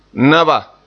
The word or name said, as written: naba